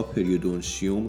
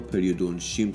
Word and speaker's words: پریودونتیوم: توی دنتکست قبلی من بعضی جاها پریودونتیوم رو پریودونتیم تلفظ کردم که اشتباهه،